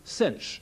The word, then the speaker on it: sinh: And the hyperbolic sine is the y-coordinate of p, and that's written S-I-N with an H, hyperbolic sine, pronounced sinh.